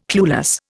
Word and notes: clueless: Indian

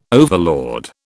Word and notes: overlord: British English

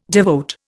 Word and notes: devote: Indian

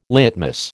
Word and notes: litmus: American English

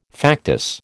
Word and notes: factice: American English

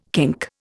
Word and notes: kink: Indian

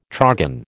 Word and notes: trogon: American English

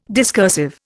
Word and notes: discursive: Indian

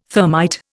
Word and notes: thermite: Indian

